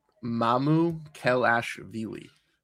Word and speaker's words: Mamukelashvili: Sandro Mamukelashvili.